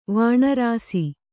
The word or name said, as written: ವಾಣರಾಸಿ